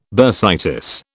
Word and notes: bursitis: British English

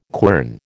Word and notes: quern: American English

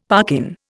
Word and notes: parkin: Indian

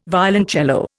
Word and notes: violoncello: Indian

